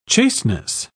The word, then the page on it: chasteness: British English